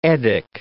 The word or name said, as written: Eddic